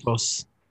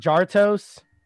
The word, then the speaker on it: Jarritos: How do you pronounce this? Yeah, so, you're fluent in Spanish. – Jarritos. – Jarritos. – Yeah. – Okay. Yeah. So I got the line. – I saw. Did y'all? Why, did y'all see that one TikTok of that one gringo trying to pronounce it? He was like Jartos.